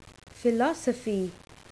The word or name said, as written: philosophy